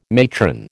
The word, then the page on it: matron: American English